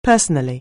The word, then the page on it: personally: anglais